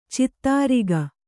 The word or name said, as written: ಛತ್ರಕ